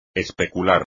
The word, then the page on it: especular: espagnol